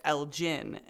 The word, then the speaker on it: Elgin: But two, because there is a town of the same name near me that is pronounced Elgin, and so I would not have pronounced it correctly.